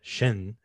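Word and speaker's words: Jeanne: as well as the confession letter that Joan, or Shin, as it's pronounced in French, says towards the, signs rather, towards the last third of the picture.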